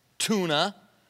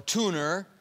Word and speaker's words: tuna: Under the earth, bugs, such as are in the sea, sharks, whales, tuna, or tuner, if you're from Boston. There's no R in it, by the way.